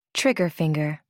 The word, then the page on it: trigger finger: American English